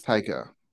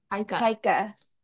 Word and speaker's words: Taika: How do you say his first name? Taika Waititi? – Taika. – Taika.